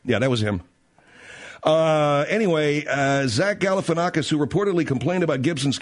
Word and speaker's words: Galifianakis: I'm not even pronouncing it right. Oh, Galifianakis. Yeah, Zach Galifianakis.